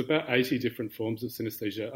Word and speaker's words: synesthesia: I read on your website you've got a condition called synesthesia.